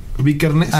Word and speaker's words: Vikernes: Dead se escribe P-E-L-K, una cosa así. Se, se pronuncia Pele. Ajá. Bar, Bar Vickers. Ajá. Vikernes.